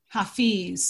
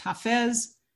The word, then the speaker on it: Hafez: The 14th century Persian mystic and lyric poet Hafiz, sometimes called Hafiz, sometimes pronounced Hafez.